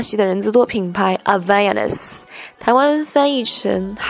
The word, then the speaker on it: Havaianas: 这个字呢是葡萄牙文，跟英文发音规则有点不一样。这个词组以下有四个音节，重音在第三音节啊，H 是不发音的，字母 A 发啊的音，E 发 e，所以念起来就像是中文的 Havaianas。